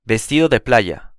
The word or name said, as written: vestido de playa